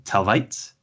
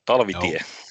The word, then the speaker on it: Talvitie: I think we were looking at New Jersey Devils prospect, potentially, Arni Talvitie. Am I saying that right? – No, Talvitie.